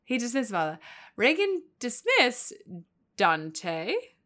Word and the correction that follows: détente: Détente. – It's because you say it and then I look at the word and right. I can't. It doesn't look how it's spelled because it's French.